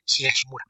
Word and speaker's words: Higashimura: Ok, mejor edición estadounidense de material internacional, Tokyo Tarareba Girls, de Akiko Higashinamura. ¿Higashinamura o Higashinamura? No sé. – Pues pusiste una sílaba, además no tiene ningún na. Es Higashimura.